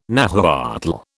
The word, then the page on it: Nahuatl: British English